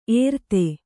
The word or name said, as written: ಏರ್ತೆ